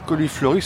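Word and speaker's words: cauliflorie: La coliflorie, ça s'écrit C-A-U, donc coliflorie, c'est-à-dire que les fleurs sortent directement de la branche.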